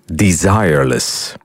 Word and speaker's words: Desireless: Blijkbaar moet je het wel op zijn Engels uitspreken. Desireless.